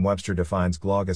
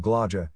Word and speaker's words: Glögg: But although Glögg may look like it should rhyme with that other notable holiday beverage eggnog, the two aren't quite a perfect match. The O in Glögg is pronounced like either the U in nut, the U in foot, or the more foreign-sounding oi in boeuf, the French word for beef.